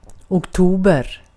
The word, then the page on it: lokakuu: /ˈlo.kɑˌkuː/